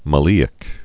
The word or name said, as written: maleic acid